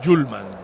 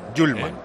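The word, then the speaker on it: Hjulmand: Paquito, por cierto, para pronunciarlo bien, me ha escrito Hjulman. Miguel Ángel de Copenhague, nuestro amigo y oyente fiel, se dice Hjulman. – Hjulman. – Doble L. Hjulman. Vale. Me ha escrito, dice, lo demás está bien, pero este tienes que decirlo Hjulman.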